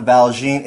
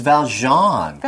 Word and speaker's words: Valjean: It's not Valjean, it's Valjean.